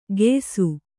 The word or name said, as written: ಗೆಯ್ಸು